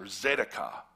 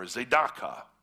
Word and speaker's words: tzedakah: Justice is often paired with a word in the Old Testament called righteousness, or tzedakah, or tzedakah, or some cool Hebrew pronunciation.